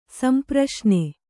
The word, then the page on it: ಸಂಪ್ರಶ್ನೆ: kannada